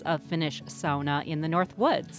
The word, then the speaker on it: sauna: Well, the way to go is sauna, as in the Finnish pronunciation. It's really the one Finnish word that is generally well known in English, and so I think we should give tribute to them.